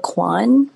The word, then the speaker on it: Kwon: So it's Brenda Kwon.